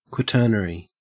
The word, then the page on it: quaternary: Australian English